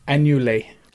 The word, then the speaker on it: annulé: We get a swan song necklace, a grimoire annuller, which is magic ward, which nullifies the next spell. – It's pronounced annulé. – Annulé, sorry.